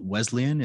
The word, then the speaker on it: Wesleyan: So before we hit the record button, you said that there was a story behind you going to Wesleyan.